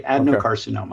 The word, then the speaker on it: adenocarcinoma: Adenocarcinoma.